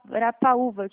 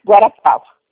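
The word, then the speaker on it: Guarapuava: Guarapaúva, que fala? – Guarapava.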